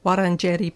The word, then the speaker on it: Wurundjeri: We're broadcasting live, as usual, from the 3CR studio on the unceded land of the Wurundjeri. Wurundjeri. I've just learnt the proper pronunciation. Wurundjeri people of the Kulin Nation.